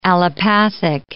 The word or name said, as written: allopathic